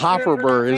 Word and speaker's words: Hofferberg: We'll make it third and about Hofferberg is spelled H-O-F-F-E-R-B-E-R, and mentally you always want to insert Berger in there for some reason, but Hofferberg is a young man's name, and he's done a good job last two games here for Blue Hill.